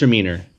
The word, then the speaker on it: Gewürztraminer: I'm probably decimating that pronunciation. – I think it's Gewürztraminer.